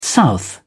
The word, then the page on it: south: British English